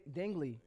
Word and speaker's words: Dingle: Name, last name is Dingley.